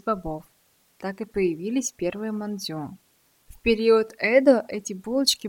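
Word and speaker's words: мандзю (まんじゅう): Китайский иероглиф «мантоу» на японском записывается аналогично, но читается как «мандзю».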